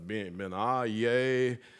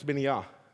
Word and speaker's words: Benaiah: And I just kept, everybody kept saying it wrong, or I did anyway. I was like, Benaiah. And I finally went to the little Hebrew translation thing. And it's simple. It's Benaiah. Benaiah.